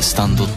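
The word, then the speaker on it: 幽波紋: スタンドっていうのは、あのすいません説明させていただくんですけど、 3 部から登場したこう、あの漢字で幽霊の霊に波紋って書いてスタンドって読むんですけど、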